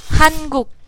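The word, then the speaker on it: Hankuk (한국): Hankuk? – Hankuk means Korea. – Han-guk. Oh, that means Korea? – Yes. – Oh. – Interesting. – Hankuk. – How's it pronounced again? – Hankuk.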